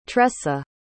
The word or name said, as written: tresa